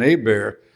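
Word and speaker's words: Hébert: Mary was an A-bear before I married her. H silent, T silent, accent aigu on the E, pronounced A-bear.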